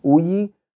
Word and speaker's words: Uyi: My first name is Uyi. It's 3-letter words, very simple to spell. I'll spell it for you. U for umbrella, Y for Yankee, I for India.